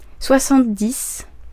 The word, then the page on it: soixante-dix: /swa.sɑ̃t.dis/; /swa.sɑ̃.tə.dis/